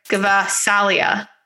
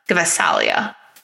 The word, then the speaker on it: Gvasalia: It's, you have to pronounce the G. It's like Gvasalia. Gvasalia.